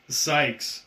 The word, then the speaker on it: Sykes: S-I-K-E-S. – You Grant Kite? – Sykes. – Oh, I was thinking you said Grant Cuts.